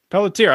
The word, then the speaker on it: Pelletier: Pelletier.